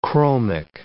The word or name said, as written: chromic